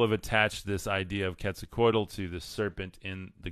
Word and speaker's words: Quetzalcoatl: And Quetzalcoatl is, it's Spanish pronounced Quetzalcoatl. And let's see.